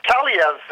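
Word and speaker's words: Kaliyev: Well, I mean, Kayalev is how you pronounce his name, and 51 goals, 51 assists.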